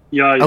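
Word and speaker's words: Yahya: I'm going to butcher his name. It's Yaya, correct? – Yeah. – Yaya.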